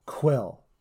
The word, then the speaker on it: Kuiil: The character's name is Kuili. Is K-U-I-I-L. And, dude, I just blew my own mind. It's played by Nick Nolte. – Quill. – Thank you. You're right.